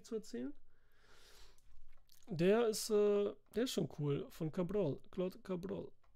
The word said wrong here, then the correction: Chabrol: Oder Chabrol, wie wird der nochmal ausgesprochen? Chabrol dann, ne?